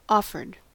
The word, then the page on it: offered: American English